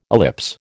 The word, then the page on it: ellipse: American English